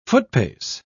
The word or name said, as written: footpace